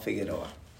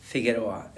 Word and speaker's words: Figueroa: Wilson Chabal Figueroa. – And can you spell it for us, please? – Sure. First name Wilson, W-I-L-S-O-N. Last name Trabal, T-R-A-B-A-L hyphen Figueroa, F-I-G-U-E-R-O-A.